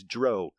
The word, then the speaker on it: drow: Who invented drow? Oh, God. – Beep. – Let's see. No, I'm fine. The official second edition drow handbook states it rhymes with cow and how.